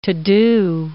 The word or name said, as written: to-do